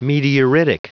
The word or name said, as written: meteoritic